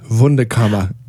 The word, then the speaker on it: Wunderkammer: And I want to make sure it's pronounced Wunderkammer. – If you are being a proper German, yeah. Then it's, yeah. So Germans have switched their V's and W's, but we don't care how people say it.